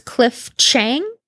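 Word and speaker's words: Cliff Chiang: And the artist is Cliff Chang. I don't know if I'm pronouncing that right, but that's what I'm going with for now. – It is.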